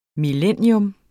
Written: millennium

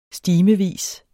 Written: stimevis

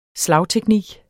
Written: slagteknik

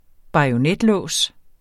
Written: bajonetlås

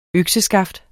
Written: økseskaft

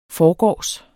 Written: forgårs

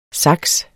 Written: sax

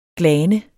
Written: glane

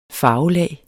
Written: farvelag

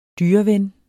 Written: dyreven

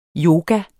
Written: yoga

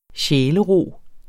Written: sjælero

